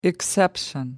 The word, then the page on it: exception: American English